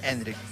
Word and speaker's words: Endrick: Eso descarta la entrada de Endriki. – Puede ser. – Yo quería ver a Endriki. – No me estás cerrando del todo la pronunciación. – Endrick, ok.